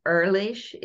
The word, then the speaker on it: Ehrlich: it's actually it's actually Ehrlich. – It rhymes with girlish.